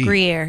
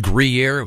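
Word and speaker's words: Gruyère: G-R-U-Y-E-R-E. – Gruyere, yeah. – And then he wraps up this message. That's pronounced Gruyere. The U is pronounced like an E.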